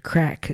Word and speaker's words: craic: And crack is spelt C-R-A-I-C.